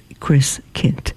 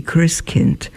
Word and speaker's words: Christkind: pronounced as Christkind. It ends with a D, but it's pronounced with a T, Christkind.